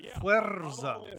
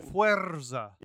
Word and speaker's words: Fuerza: I'm all in for Oklahoma State versus Puerto Fuerza. Like, this is going to be great. Did I say that right? Puerto Rico Fuerza. So, Fuerza. Fuerza. – Yes.